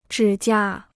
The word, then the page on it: 指甲: zhǐ jia